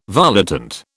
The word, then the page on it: volitant: British English